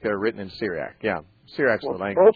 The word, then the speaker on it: Syriac: am I saying this right? The Syriac. S-Y-R-I-A-C, yeah? – Yeah, oh, yes, Syriac, right. Mm-hmm.